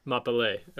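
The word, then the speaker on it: Mapalé: Can you say that again? Mapfe? – Mapale. – Mapale. Okay. – Yep. – Cool. – Yeah, it's a M-A-P-A-L-E with an accent on the E if anybody wants to check it out.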